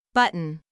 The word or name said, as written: button